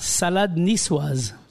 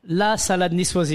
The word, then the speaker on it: salade niçoise: Salad niçoise. – What happened to the la? – La salade niçoise, if it was written down.